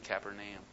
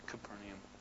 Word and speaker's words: Capernaum: You could, yeah, I pronounce it Capernaum, but Capernaum, yeah.